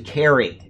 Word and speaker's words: Cery: I've been saying Siri. His name is spelled C-E-R-Y. It's actually Kerry. He's in England, and I didn't know that they pronounce C-E with as a K.